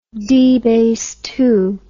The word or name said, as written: dBase II